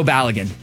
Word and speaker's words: Balogun: And then Balogun, is that how you say it? – Flo Balogun.